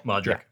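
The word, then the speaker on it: Modrić: Has Luka Modric? Is that how you say it? – Yeah. – Modric.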